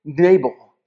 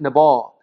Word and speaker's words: Nabal: Now, the name of the man was Nabal. Uh, that's how they say it in the South. We would actually pronounce it Nabal.